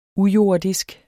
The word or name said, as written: ujordisk